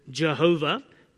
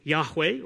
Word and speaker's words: Yahweh: In the old days, it was translated as Jehovah, because that's how they thought the Hebrew word was pronounced. But of course, it's been demonstrated that's probably not how it's pronounced, and it's pronounced probably more correctly as Yahweh, or just the consonants YHWH. That's God's personal name.